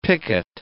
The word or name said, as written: picket